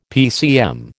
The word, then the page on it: PCM: American English